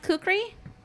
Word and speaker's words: kukri: So the terrified Anne goes around until is it pronounced kukri? – Sure.